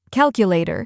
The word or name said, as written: calculator